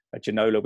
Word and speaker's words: Ginola: He's spelled G-E-N-O-L-A, but obviously it's supposed to be G-I-N-O-L-A. Ginola, we called him for a long time. Then Ginola, I think, is the French pronunciation.